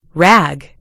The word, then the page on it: rag: British English